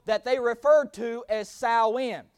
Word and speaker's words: Samhain: Samhain is the way it's pronounced. Now, if you read it or want to write it down, it's spelled Samhain. So it looks like it says Samhain. It is pronounced Samhain, okay?